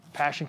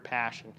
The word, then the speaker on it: passio: I don't even know how to pronounce the word, but it's P-A-S-S-I-O. Anybody speak Latin? Want to throw it out there? No? All right. Dead language. Passio. Let's call it passio.